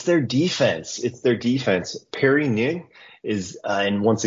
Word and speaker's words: Ng: I think they pronounced it NG on FIFA. – NG, yes.